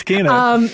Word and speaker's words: Kena: I believe I've been saying Kena this whole time and now I have to reconfigure my mind. – Apparently it's Kena.